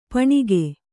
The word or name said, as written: ಪೂಣಿಗ